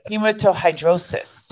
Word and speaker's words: hematohidrosis: Can you pronounce that word? – And I'm trying. Hematohidrosis. – Oh, look at you.